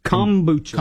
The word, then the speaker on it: kombucha: Now, how do you say it again? Is it kombucha? – Kombucha. – Kombucha. Like, the M. – Combine. – So, yes, combine. – So yes on the M.